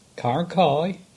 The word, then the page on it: Carnkie: English